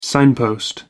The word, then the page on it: signpost: American English